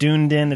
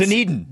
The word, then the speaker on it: Dunedin: It's not Dunedin. It's not Dunedin. It's Dunedin. – Dunedin.